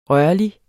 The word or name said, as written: rørlig